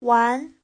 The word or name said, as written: wan2